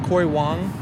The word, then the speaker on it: Corey Wong: Corey Wong? Am I pronouncing that band right? Corey Wong? – Yeah.